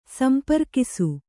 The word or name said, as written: ಸಂಪರ್ಕಿಸು